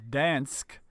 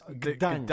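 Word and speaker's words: Gdańsk: But we're told that in 1308, when they enter the city of Dansk, Gdansk. – Yeah. – Now this shit is important. It's Gdansk.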